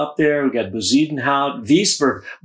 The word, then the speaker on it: Wiesberger: Yeah, Bernd Wiesberger. – Wiesberger. – Wiesberger, thank you for the pronunciation there.